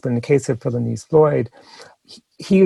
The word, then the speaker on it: Philonise: Well, I'm still thinking about the testimony before Congress last week of the brother of George Floyd, Philonise Floyd. Am I pronouncing his name right? – Philonise, yeah.